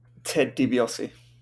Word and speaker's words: Ted DiBiase: Ted DiBiase. – That's correct. And you said his name right for a bonus point.